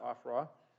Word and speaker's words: Ophrah: And the angel of the Lord came and sat down under an oak in Ophrah, that's how you pronounce that, Ophrah, that belonged to Joash the Abiezrite, where his son Gideon was threshing wheat in the winepress to keep it from the Midianites.